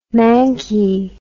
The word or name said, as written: mankie